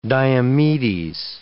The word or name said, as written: Diomedes